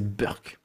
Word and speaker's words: Burke: c'est Burke, Edmund Burke, prononcé Burke.